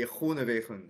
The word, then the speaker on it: Groenewegen: On a vu, Johan, on avait un très très très beau sprint entre Consoni et, et Groenewegen. – bien sûr, c'est comme ça qu'on prononce, c'est vrai.